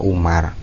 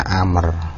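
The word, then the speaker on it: Umar (عمر): Jadi huruf ain, mim dan ro itu kalau tanpa wawu setelah ro itu bacanya Umar. Tapi kalau ada wawunya bacanya Amr. Tapi karena di sini tidak ada wawunya maka bacanya Umar.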